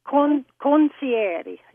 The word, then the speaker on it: concierge: Concierge. It's kind of a silent G.